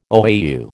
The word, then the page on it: OAU: American English